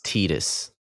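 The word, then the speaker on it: Tidus: But there's it's pronounced Titus.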